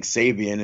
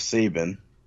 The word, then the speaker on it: Sabian: Which, by the way, they're now pronouncing Sabian instead of Sabin.